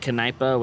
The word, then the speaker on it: Kneipe: If there's an E, well, in German, you would pronounce the K. Like Kneipe, which is like a theater. Or a bar. A bar is a Kneipe.